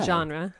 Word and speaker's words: genre: Genre. – Oh, that's not how you say it. – It is in French. Genre.